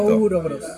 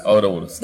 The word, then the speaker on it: Ouroboros: Es O-U-R-O-B grande O-R-O-S. Perfecto. Ourobros Ouroboros.